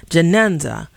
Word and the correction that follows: Janaza: Did I get it correct? – Janaza.